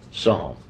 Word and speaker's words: Psalms: But we don't pronounce the P, so it's okay to call it Summer Psalms.